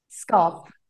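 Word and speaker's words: skaap: And in Afrikaans, sheep is pronounced skap. Is that right? Skop. – Yeah, skop.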